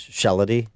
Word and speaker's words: Shalady: This morning is Scott, the cow guy, Shalady. – Good morning. – I think I've said this before because I've been corrected. I think the proper pronunciation of your name is Shalady. – Rhymes with melody.